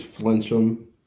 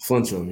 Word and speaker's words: Flinchum: It's Flinchum. – Flinchum. – Flinchum, yeah.